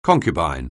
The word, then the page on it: concubine: anglais